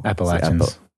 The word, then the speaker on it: Appalachians: Appalachians. – I just want to say that's where I'm from. – It's an A. – It's a correct pronunciation.